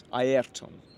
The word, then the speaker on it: Ayrton: Ayrton, because that's how you should pronounce it, not Ayrton.